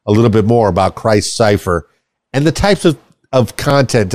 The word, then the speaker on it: Christ Cipher: Christ Cipher. Christ, spelled like Jesus Christ, C-H-R-I-S-T, Cipher, C-Y-P-H-E-R.